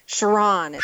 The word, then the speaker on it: Chauran: Exonation, my guest this hour is Dr. Alexandra, and I hope I'm pronouncing your family name right. Chauron? – Chauron. – Chauron. – But good try.